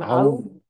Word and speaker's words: Aoun: Aoun, Aoun.